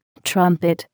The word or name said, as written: trumpet